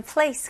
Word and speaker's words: place: 영어로는 그냥 이름절로 하셔야 돼요. 그래서 여기 있는 소리들 place 연결해서 이름절 place.